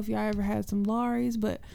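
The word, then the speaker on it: Lawry's: Now I know y'all know what the Laurie's is, and you got to say it like that, Laurie's, the Laurie's, and it's red.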